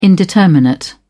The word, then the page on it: indeterminate: British English